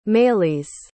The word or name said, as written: maëlys